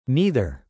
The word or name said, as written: neither